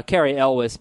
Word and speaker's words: Cary Elwes: Uh, Cary Elwes, by the way, is how you pronounce it.